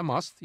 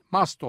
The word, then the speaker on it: must: Okunuşu da bu iki lisanın bir karışımı ve mast kelimesi sanılanın aksine mus değil mast olarak yani İngilizce telaffuz edilmek zorunda.